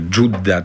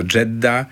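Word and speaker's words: Jeddah: Myślę, że ta dyskusja na temat wymowy będzie trwała zawsze, tak jak my w naszych dokumentach stosujemy nazwę Dżudda często. – O tak. – A inni mówią to Dżedda.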